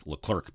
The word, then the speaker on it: Leclerc: Johnny Leclerc is Leclair. – Yeah. – And Charles Leclerc is Leclerc, basically. L-E-C-L-E-R-C. – Oh, that's full French.